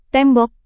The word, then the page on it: tembok: indonesian